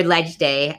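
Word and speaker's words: Leg Day: put in the comments the link to, I'd gone to my first legislative day, or Ledge Day, as people in the know call it. But you spell it L-E-G, but pronounce it Ledge, which is always confusing to me.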